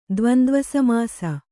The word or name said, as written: ದ್ವಂದ್ವಸಮಾಸ